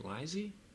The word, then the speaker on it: Lisey: Lisey's Story. – What doing? – I had to look it up how to pronounce it because it's spelled L-I-S-E-Y. And I'm like, Lisey? Lisey? It's Lisey.